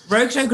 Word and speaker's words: Rotog: Rotog reviewer sounds a bit like record reviewer, doesn't it? Record reviewer. That's how I can remember how to pronounce it. Just say record reviewer. – Rotog review. – Rotog.